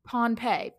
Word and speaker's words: Pohnpei: Pohnpei? – Not Pompeii, not Indonesia, but Pohn. – How do you spell it? – Pohnpei, Pohnpei. – P-E-I.